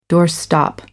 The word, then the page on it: doorstop: American English